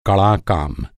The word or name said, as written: कड़ा